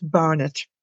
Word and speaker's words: Barnet: But Barnet, it's a kind of Cockney slang for hair. You're Barnet. I don't know where it came from. So it's like bar and a net, so it's Barnet.